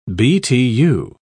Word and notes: btu: British English

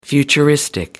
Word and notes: futuristic: American English

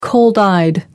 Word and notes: cold-eyed: American English

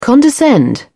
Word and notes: condescend: British English

